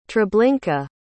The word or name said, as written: treblinka